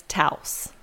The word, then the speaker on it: Taos: I believe it's Taos. – Okay, as in rhymes with house.